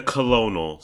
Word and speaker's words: Colonel: Today we tasted the new chicken sandwiches from KFC, aka Kitchen Fried Chicken, aka Kentucky Fried Chicken, aka the Colonel Sanders. No, the L is silent in Colonel, honey.